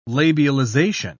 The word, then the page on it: labialization: British English